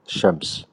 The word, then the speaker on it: الشمس: الشمس بحسب القاعدة اللي حكيناها هلا الشمس الشين مشددة